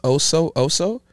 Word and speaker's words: Oso Oso: I believe it's pronounced Oso, Oso. So O-S-O-O-S-O.